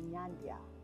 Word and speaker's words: Nyandia: And this is none other than Nyandia.